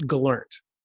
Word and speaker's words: Gellert: No one really knows how to say Lee's name. Even I feel like he mispronounces it too. I believe it's Gellert.